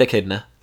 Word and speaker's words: Echidna: The Echidna? – Yeah. – Is that how you say it? – The Red Echidna.